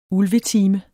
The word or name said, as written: Ulvetime